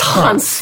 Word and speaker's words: Hans Zimmer: Ones? – I interviewed Hans Zimmer a couple of weeks ago. Never calling Hans. It is Hans. – Hans. – It's Hans.